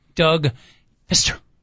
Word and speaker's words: Fister: I believe his name is Doug Fister. – Doug? – No silent P, by the way. It's F-I-S-T-E-R.